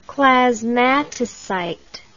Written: clasmatocyte

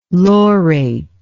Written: laurate

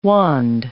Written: wand

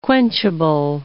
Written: quenchable

